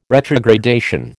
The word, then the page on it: retrogradation: American English